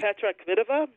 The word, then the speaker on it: Petra Kvitova: Going in, is she Petra Kvitova? – Thank you.